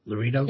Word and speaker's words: Loretto: L-O-R-E-T-T-O. How am I pronouncing that? Loreto? – Yeah, Loreto. Yeah.